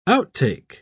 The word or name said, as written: outtake